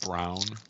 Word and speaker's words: Braun: You know, it's pronounced brown. – Is it? – It is, yes. – Is it brown? Yeah.